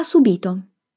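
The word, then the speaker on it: subìto: Ha subito. Verbo al passato prossimo, dall'infinito subire. Attenzione all'accento di questa parola. Non confondetevi con subito. Subito, subito.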